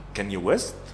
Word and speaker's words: Kanye West: Estuvo con Kanye West.